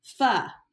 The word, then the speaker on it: pho: I was putting on mascara during the faux voicemail or pho. It's pho. That's how you say it.